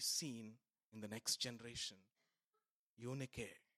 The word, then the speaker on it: Eunike: Eunike. You means good. Nike means victory. Nike. Nike means victory. That is how it is pronounced, by the way, if you didn't know that.